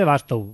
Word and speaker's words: Bastow: Vamos con Tommy Baslow. O Bastow. No lo veo bien. – Mira, se escribe Bastow.